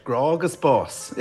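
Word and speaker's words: Grá agus Bás: Nous écoutons en ce moment le chanteur Jarla O'Lionheart dans votre œuvre de 2007, Gra Agus Bas. Est-ce que je le prononce bien? – Yes, that's perfect. Gra Agus Bas, love and death.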